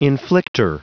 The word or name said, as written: inflictor